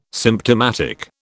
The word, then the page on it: symptomatic: British English